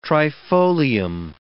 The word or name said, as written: trifolium